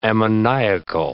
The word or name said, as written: ammoniacal